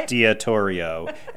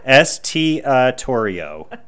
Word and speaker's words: Estiatorio: Estiatorio. Estiatorio.